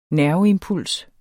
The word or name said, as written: nerveimpuls